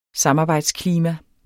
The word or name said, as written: samarbejdsklima